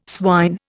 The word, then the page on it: swine: Indian